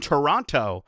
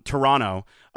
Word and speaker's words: Toronto: And then lastly, we are going to be covering Fan Expo in Toronto, which is the wrong way to pronounce that, by the way. Sorry. I apologize to all my mandem. Toronto.